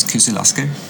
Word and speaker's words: Kyzyl Asker: So, you just came back from China, I don't know how to pronounce this, Kyzyl Asker, is it? – Perfect.